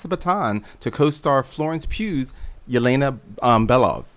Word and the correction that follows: Belova: Belov, excuse me, Belova.